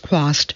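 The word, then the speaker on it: Chwast: Trost, pronounced Quast.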